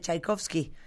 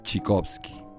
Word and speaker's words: Tchaikovsky: pero en Rusia les da mucha risa que uno diga Tchaikovsky, porque no se pronuncia así. – ¿Cómo se, pronuncia? Tchaikovsky. – Tchaikovsky.